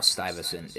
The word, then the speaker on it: Stuyvesant: I'm also really happy that I learned how Stuyvesant is pronounced because it's one of those yeah, yeah it's one of those yeah.